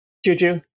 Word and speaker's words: Juju: How do you say Juju anyway? Because I say Juju because that's how you say in Portuguese, which is so cute. How do you say it? – Juju. – The Juju. – Hard, yeah.